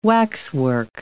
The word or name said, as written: waxwork